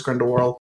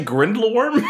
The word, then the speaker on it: Grindelwald: Did you say Grindelworm? – It was a little bit more like Grindelwur, because there was no consonant after what that L became an R when I was saying it.